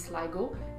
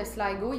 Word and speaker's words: Sligo: Je, pense qu'en irlandais, ça se dit Sligo. Euh, ça s'écrit S-L-I-G-O.